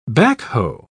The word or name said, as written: backhoe